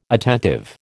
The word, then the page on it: attentive: American English